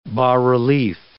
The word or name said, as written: bas-relief